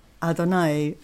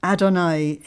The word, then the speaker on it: Adonai: And I want to give you the Hebrew words, Ruach Adonai, or R-U-A-C-H. R-U-A-C-H, Ruach Adonai, A-D-O-N-A-I.